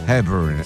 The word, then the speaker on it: Hepburn: Hai visto come si pronuncia? Come si pronuncia? – Hepburn. – Ecco.